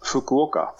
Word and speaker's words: Fukuoka: Vi fortsätter till Fukuoka, om jag uttalat det rätt. – Fukuoka. Ja.